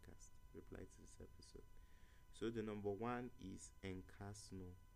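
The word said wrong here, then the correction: Escanor: Okay, Escano. I've always pronounced it as Encarno.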